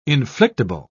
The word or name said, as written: inflictable